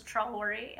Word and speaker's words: Traoré: And during that lull, I believe it was Traoré, am I saying his name correctly? – Mm-hmm.